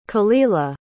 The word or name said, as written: kelilah